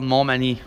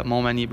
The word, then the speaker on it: Montmagny: Yeah, it's called Montmagny. – Right. – Yeah, Montmagny.